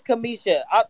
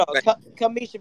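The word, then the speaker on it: Cameshia: But hold up, it's Kamisha. I'm not trying to be, you know, my name is always mispronounced, but it's Kamisha Reviews.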